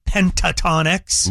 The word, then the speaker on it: Pentatonix: We're giving away tickets to see the Pentatonix.